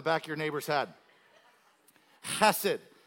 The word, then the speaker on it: hesed: And so the Old Testament was written in Hebrew, and the word for mercy here is the Hebrew word chesed hesed. You have to, it's an H E S E D, but you have to pronounce it like it's K H, like you're hawking up a loogie.